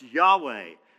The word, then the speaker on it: Yahweh: And if you add the vowels, you see the A and the E, it's pronounced Yahweh.